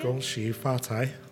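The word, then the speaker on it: Gong shi fa tai (恭喜发财): How do you say it in Mandarin again? – Gong shi fa tai.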